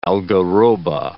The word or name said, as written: algarroba